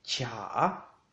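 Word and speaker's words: cha (茶): T en chino se dice cha, cha, cha, con un tono en la, cha.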